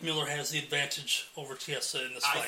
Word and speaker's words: Chiesa: I think that Chelsea... How do you say it? – Chiesa. – Gee, my goodness. Chiesa, I think he's hungrier.